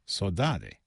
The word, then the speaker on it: saudade: In Mexico, if I'm not mistaken, that Portuguese word of longing for something that is absent is pronounced saudade.